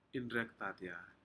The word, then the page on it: inrekdanya: Aneityum